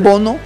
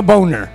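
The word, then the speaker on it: Bounou: Es que lo escriben Bonu, pero lo pronuncian Bono. – No, no, con... Bono, no Boner.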